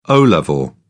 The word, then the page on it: O level: British English